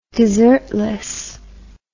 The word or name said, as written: desertless